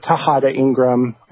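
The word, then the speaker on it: Tejada-Ingram: And you can just search that, or really just search my name, Ben Tejada Ingram, and that's spelled T-E-J-A-D-A hyphen I-N-G-R-A-M.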